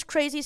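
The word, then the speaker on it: Krazey: My stage name is Crazy Stacy 25.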